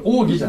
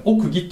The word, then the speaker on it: 奥義: ん、まちょっと文的に難しいかもしれませんが何を言ってるかというと、えー、神の中に世々隠されていた奥義があったのですよでこの奥義という言葉はあのこれ奥義じゃなくて奥義って読む